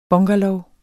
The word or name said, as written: BUNGALOWERNES